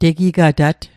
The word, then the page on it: tegigata't: Mi'gmaq